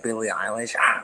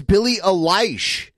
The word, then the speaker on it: Billie Eilish: Dude, he said it right. Dude, normally he says Billie Eilish. But he said it correctly in this private conversation. It wasn't even private, but it wasn't on his channel. I'm like, hold on. You said that right this time.